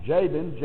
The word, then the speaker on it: Jabin: Jabin, Y-A-B-I-N, which we pronounce Jabin, J-A-B-I-N.